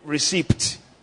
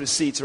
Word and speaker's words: receipt: You know, some people say receipt, all right? But I think it's pronounced receipt, right? The P is silent.